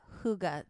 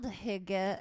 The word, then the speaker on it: hygge: Hygge. – Oh, I thought it was pronounced hygge. – Yeah. No, it's hygge? Spelled hygge, H-Y-G-G-E, but it is pronounced hygge.